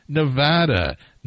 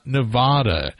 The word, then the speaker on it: Nevada: It's Nevada, not Nevada.